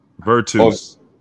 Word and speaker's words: Virtus: Virtus Roma? Is that how I'm pronouncing it? – Virtus.